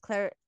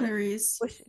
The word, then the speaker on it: Clarice: And I really like the scene after with Claire, Clarice, Clarice. Yeah, I don't know. I keep saying her name wrong. Clarice.